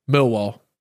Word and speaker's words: Millwall: Millwall. Say again. – Millwall. – Now you're saying it right.